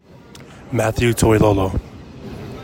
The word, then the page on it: Matthew Toilolo: MAH thyew TOY low low